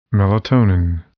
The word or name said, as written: melatonin